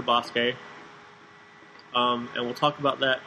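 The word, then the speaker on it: Basuke (バスケ): So it's spelled basket, but I think it's pronounced Basuke. – Basuke.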